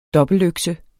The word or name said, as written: dobbeltøkse